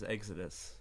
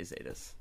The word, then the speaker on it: XETAS: We've heard everything from like Chetas to Hetas. – Exodus. – Yeah, my mom says Exodus. – Exodus would be kind of cool, actually. It's like as a pronunciation, because right? – My mom thinks it's like Exodus. – Yeah, like the thrash band. – Yeah. – But no, we say Zetas.